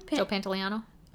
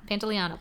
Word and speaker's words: Pantoliano: Joe Pantoliano. – Okay. Pantoliano. – Pantoliano.